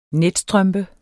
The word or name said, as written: netstrømpe